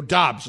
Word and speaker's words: Doubs: It was Aaron Rodgers pointing out his rookie wide receiver, Romeo Dubs, who is doing, quote, Romeo Dobbs, it's pronounced, D-O-U-B-S, yeah. – pronounced that way.